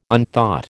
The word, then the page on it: unthought: American English